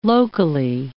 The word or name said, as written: locally